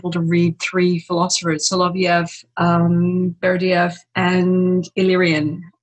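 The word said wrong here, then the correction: Ilyin: uh, who's I uh, probably mispronounced. – Um, Ilian, Ilian.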